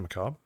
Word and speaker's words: Macabre: Macabre or Macabre? – Isn't it Macabre? – That's the American pronunciation. You're Yankee dog. Macabre.